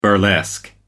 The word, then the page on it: burlesque: American English